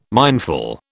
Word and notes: mindful: British English

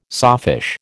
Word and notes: sawfish: American English

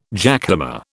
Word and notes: jackhammer: British English

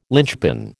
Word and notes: lynchpin: American English

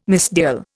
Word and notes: misdial: Indian

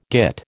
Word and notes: git: American English

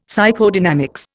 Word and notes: Psychodynamics: Indian

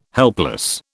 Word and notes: helpless: British English

